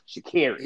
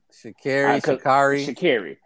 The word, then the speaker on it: Sha'Carri: And you combine that with, at the same time, the now, please correct me, the proper pronunciation for this young woman's name is Sha'Carri Richardson, correct? Sha'Carri. – Yeah. Sha'Carri.